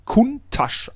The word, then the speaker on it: Countach: Und er wird ganz schlicht und ergreifend Kuntasch ausgesprochen. Wie die Menschen da vor Ort sprechen, wie die Mundart, das ist die korrekte Aussprache des Lamborghini.